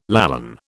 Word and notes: Lallan: British English

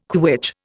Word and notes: quitch: Indian

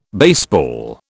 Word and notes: baseball: British English

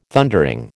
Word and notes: Thundering: American English

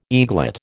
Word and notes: eaglet: American English

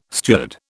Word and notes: steward: British English

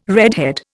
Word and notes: redhead: Indian

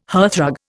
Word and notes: hearthrug: Indian

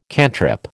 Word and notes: cantrip: American English

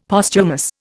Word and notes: Posthumous: Indian

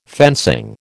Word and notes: fencing: American English

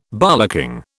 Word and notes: bollocking: British English

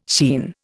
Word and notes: sheen: Indian

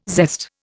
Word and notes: zest: Indian

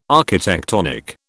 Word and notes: architectonic: British English

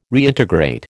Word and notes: Reintegrate: American English

